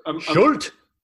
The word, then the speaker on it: Schulte: Schulte. You got to say it like Schulte.